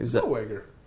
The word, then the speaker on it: Zellweger: Zellweger? – Zellweger.